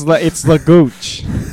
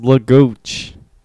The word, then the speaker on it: Lagooch: No, it's Lagooch. – It's Lagook. – You know, like Gucci's spelled with a C. – Is this just... Hold on. Lagook? – No, it's not Lagook. It's Lagooch.